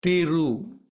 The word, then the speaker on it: Thiru: The first two syllables are Thiru, T-H-I-R-U.